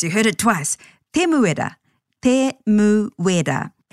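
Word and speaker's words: Temuera: He may actually be often known as Tim, but to pronounce his full name, Te Muera, again, stay true to the vowel sounds, a, e, I, o, u, and break the name into syllables, which will always end with a vowel.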